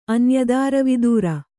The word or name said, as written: ಅನ್ಯದಾರವಿದೂರ